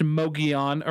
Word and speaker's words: Mogollon: It is spelled kind of like Mongolian, but pronounced Mogollon around here. Don't ask me why.